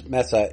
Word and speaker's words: Meta: And to be precise, Meta is pronounced like the feminine form of the Hebrew word, dead Facebook, and dead verse then.